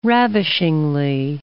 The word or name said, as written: ravishingly